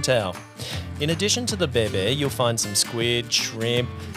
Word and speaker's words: bé bé: Our last but not least no-name venue in Vung Tau is building a fine reputation for their specialty, bún bé bé, pronounced bé bé.